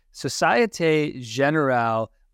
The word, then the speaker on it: Société Générale: Societe Generale, which is a very nice bank.